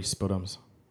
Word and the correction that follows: sputum: Sputum, I guess. – Oh, whatever, Mr. Technical. – Oh, I'm saying it wrong. – A what? – I was pronouncing it wrong.